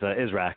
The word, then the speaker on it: Israch: Oh, no, you got pretty darn close. It's Israq, so pretty much everyone butchers it.